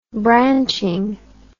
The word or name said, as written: branching